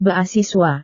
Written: beasiswa

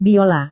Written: biola

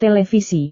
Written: televisi

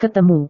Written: ketemu

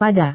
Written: pada

